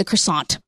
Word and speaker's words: croissant: So, first of all, the T is silent. – Yes. – No, it's not. – Yes, it is. – It's French. – Croissant? – Croissant.